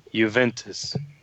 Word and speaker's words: Juventus: How do you pronounce his new team? – Juventus.